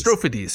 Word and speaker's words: Strophades: Strophades, sorry. Strophades. – Think of like Cyclades and Dodecades.